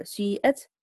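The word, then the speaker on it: سِيئَتْ: إذن وكيفية النطق مثلا في مثلا سيئت سيئت سيئت سيئة هكذا. إذن يعني قال بعض العلماء يعني تبدأ بضمة ثم تتذكر أنها مكسورة وتأتي بالكسر هكذا، يعني تأتي بحرف مركب من حركتين، تأتي تبدأ سويت سوي سوي هكذا سويت سوي.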